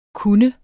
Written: kunne

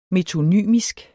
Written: metonymisk

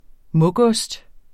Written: mugost

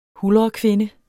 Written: huldrekvinde

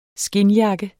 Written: skindjakke

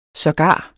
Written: sågar

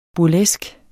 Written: burlesk

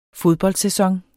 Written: fodboldsæson

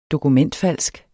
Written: dokumentfalsk

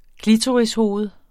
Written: klitorishoved

